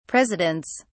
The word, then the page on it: presidents: British English